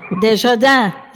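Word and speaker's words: de Chardin: You shared Rumi, and this is a quote from Pierre Teilhard de Jardin. I hope I'm saying that correctly. I know you speak French. – Desjardins. – Yeah, very good.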